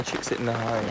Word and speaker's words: Csikszentmihalyi: Nilay Csikszentmihalyi.